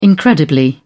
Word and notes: incredibly: British English

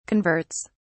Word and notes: converts: British English

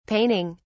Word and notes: paining: British English

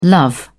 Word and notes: love: British English

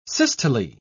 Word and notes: systole: British English

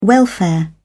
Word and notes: welfare: British English

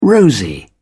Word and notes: rosy: British English

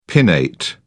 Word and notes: pinnate: British English

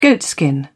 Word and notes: goatskin: British English

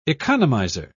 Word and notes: economizer: British English

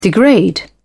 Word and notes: degrade: British English

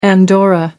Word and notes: andorra: American English

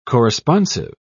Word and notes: corresponsive: British English

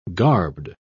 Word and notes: garbed: British English

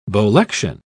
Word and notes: bolection: British English

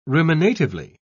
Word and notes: ruminatively: British English

